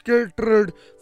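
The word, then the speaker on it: chytrid: The evolutionary history of the often overlooked lineage of citrus, pronounced citrid fungi, has vexed scientists for decades.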